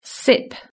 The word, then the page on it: sip: British English